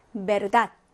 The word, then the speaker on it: verdad: 예, 영어는 V 발음이 나지만 스페인어의 우베는 네, 배와 발음이 같습니다. 비읍 발음이라고 생각하시면 돼요. 그래서 어떻게 읽어주시면 될까요? vacaciones 라고 읽어주시면 됐고 verdad 하고 읽어주시면 됩니다.